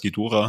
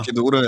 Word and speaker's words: Ghidorah: Also gerade der Ghidorah, oder? So spricht man aus, Ghidorah. – Ghidorah, ja.